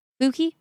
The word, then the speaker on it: Fucci: F-U-C-C-I? Fucci? How do you pronounce that? – Fucci? – Wow. – Yeah, like Stanley Tucci. T-U-C-C-I.